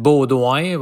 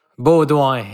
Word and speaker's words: Beaudoin: Yes, David Beaudoin. Well, I know it's hard to pronounce in English. Beaudoin, yeah.